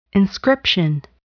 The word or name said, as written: inscription